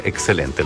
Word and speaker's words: XCLNT: Celeste, así se llama, pero en el disco lo escriben con las letras X, C, L, N, T. Se pronuncia excelente, el nuevo disco de Los Tres.